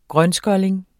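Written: grønskolling

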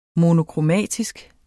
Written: monokromatisk

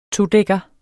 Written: todækker

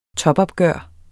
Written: topopgør

